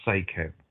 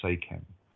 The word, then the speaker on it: Seichem: And I've always known it as Seikhem, and that is spelt S-E-I-C-H-E-M, Seikhem.